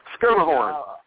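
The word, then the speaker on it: Schermerhorn: So I am Schimmerhorn, I think. How do you pronounce it? Because I don't think I've ever pronounced it the way you did. – Skimmerhorn.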